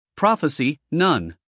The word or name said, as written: prophecy